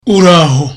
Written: uraho

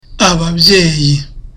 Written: ababyeyi

